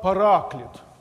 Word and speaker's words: параклит: И вот это странное слово «утешитель», по-гречески оно звучит как «параклит».